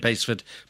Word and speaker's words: Basford: Pleased to find out that you finally pronounced Basford the right way.